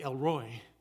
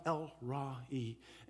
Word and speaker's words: El Ra'i: We see it almost looks like El Roy. It's pronounced El Ra'i.